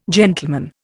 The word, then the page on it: gentleman: Indian